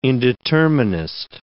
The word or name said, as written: indeterminist